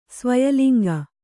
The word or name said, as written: ಸ್ವಯಲಿಂಗ